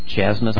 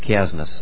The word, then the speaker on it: chiasmus: And we'll get to that in a second here, called chiasmus, or chiasmus. I call it chiasmus. That's how I pronounce it.